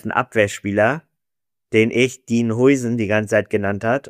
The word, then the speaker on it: Huijsen: dass er Huijsen ausgesprochen wird.